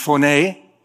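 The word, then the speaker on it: phoné (φωνή): It's actually pronounced phoné, or phones, or phonen, depending on how it's used in the scripture.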